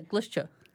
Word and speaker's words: Glosho: So we hunted for, am I saying this right, Glosho? – Yeah, Glosho.